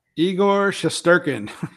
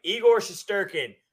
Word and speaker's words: Igor Shesterkin: You get to pronounce his name. – Igor Shesterkin. – Yes, Shesterkin. I don't know why I can never pronounce that name right. Igor Shesterkin.